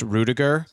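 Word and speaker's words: Rüdiger: I do want to go back to that body check on the German wall, pronounced Rudiger.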